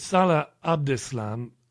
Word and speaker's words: Salah Abdeslam: So we're hearing rumors of, oh, this was revenge for the apprehension of Al-Dislami. I can't even pronounce his name correctly. I'm not sure. – No, you're close enough. It's Salah Abdeslam.